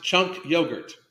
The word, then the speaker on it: Cenk Uygur: It's pronounced chunk yogurt.